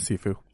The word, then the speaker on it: Sifu: Sifu.